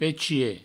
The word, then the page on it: pechier: pechie; provencal